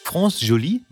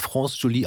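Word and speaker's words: France Joli: Und ich glaube, sie wird France Jolie ausgesprochen, weil bei Wikipedia steht, dass sie erst mit 14 Jahren Englisch gelernt hat.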